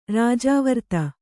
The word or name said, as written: ರಾಜಾವರ್ತ